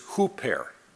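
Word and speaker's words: huper (ὑπέρ): But I do know that the prefix of the Greek word is huper. In English, it's spelled H-U-P-E-R.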